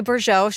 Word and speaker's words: Bourgeau: Great job with the pronunciation of the last name. I was like, but Stephanie, how do we say this? – Bourgeau.